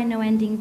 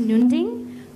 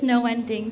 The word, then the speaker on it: Noending: In Indonesian, we pronounce it as Nunding, but I always like to pronounce it as Noending because it's a little bit more catchy in English.